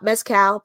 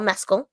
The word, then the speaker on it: Mescal: A picture came out yesterday, 2 days ago, of beloved actor of this movie fan, Paul Mescal, and that is how you pronounce his name, not Mezcal, Paul Mescal, being seen out in a Mets hat.